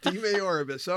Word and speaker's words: DeMaioribus: DeMaioribus.